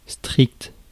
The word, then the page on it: strict: [stʁikt]